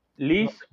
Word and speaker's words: Lise: Liz Borbeau, No sé cómo se pronuncia. – porque no se pronuncia la E al final, pero bueno.